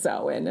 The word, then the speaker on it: Samhain: Samhain is pronounced... Samhain, as some people say, but it's Samhain.